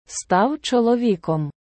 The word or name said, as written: став чоловіком